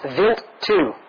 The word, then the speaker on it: Wild: New research firms up past suggestions of glycine, the simplest amino acid used to make proteins, inside samples from the comet Wild 2, pronounced Vilt 2.